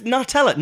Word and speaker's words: Nutella: But it's Nutella, nuts. – Yeah. – Hazelnut, Hazelnutella. – So I... – Hazelnew. – We should explain that apparently people in the US, not everyone, but a lot of people in the US pronounce Nutella, Nutella. – Yeah, well, yeah, you wouldn't say a hazelnew.